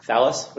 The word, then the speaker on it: Thales: Now, Your Honors, we believe this case is indistinguishable from Thales, which I learned this morning is how you pronounce that.